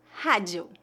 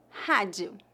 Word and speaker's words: rádio: Observe que a letra D antes da vogal I é pronunciada como di. E a vogal O no final de palavras é pronunciada como um U fraco. Rádio rádio. Novamente, lembre-se de que a letra R em início de palavras deve ser pronunciada como a letra H do inglês e a letra A tem a pronúncia bem aberta. Rádio.